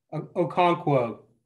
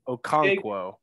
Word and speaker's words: Okonkwo: but is it Okonkwo? – Okonkwo. – Okonkwo. – Big Okonkwo, yeah.